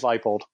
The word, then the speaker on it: Leipold: Would Buffalo coach Lance, and am I pronouncing this wrong? Leipold? – I think it's Leipold.